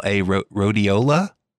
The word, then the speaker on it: rhodiola: How do you pronounce that? R-H-O-D-I-O-L-A, rhodiola? – Yeah, that's rhodiola.